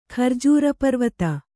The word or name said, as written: ಖರ್ಜೂರಪರ್ವತ